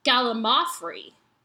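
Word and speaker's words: gallimaufry: I would have written sooner, but expected that some other nobody would have let you know by now that the correct pronunciation of gallimafree puts the emphasis on the third syllable, mao, not the second, lim, as you've been saying it. So it's gallimafree.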